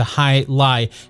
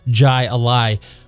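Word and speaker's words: jai alai: Thanks so much for the additional insight into high lie and for taking the time to write in, Jeff. I think throughout the entire episode of Black Mass, I mispronounced that as jai a lie.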